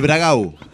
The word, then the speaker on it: Bragado: Bragado. – El Bragau, le dicen los gauchos. – Bragau. – Bragau, con la U final.